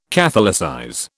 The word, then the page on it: catholicize: British English